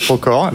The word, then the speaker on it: Procore: Ça se prononce Procore. Là, vous pourrez lire. Vous voyez, c'est très simple. Hein. C'est okay. comme ça s'écrit.